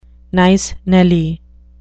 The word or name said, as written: nice Nelly